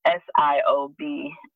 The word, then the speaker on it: Siobhan: It's Siobhan Marie0709.